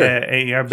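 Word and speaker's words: Bhaer: Bear, is that how would you say it? B-H... Sure. Bear sure.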